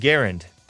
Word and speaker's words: Garand: But I learned that it's pronounced the M1 Garand because it was made by a guy named John C.